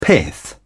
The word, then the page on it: pith: British English